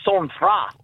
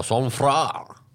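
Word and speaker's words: sang-froid: So the actual pronunciation is sans froid. – Oh, sans froid.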